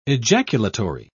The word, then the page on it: ejaculatory: British English